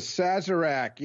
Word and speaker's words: Sazerac: The Sazerac, yes.